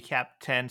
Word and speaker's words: CAIP: Actually, what is the preferred pronunciation within the Web3 community? I've always said CAP, but do people say CAPE? – I think it's CAPE, but I'm happy to accept either.